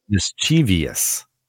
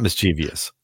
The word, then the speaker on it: mischievous: Mischievous. That's weird. – It's not mischievous. It's mischievous.